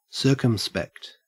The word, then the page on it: circumspect: Australian English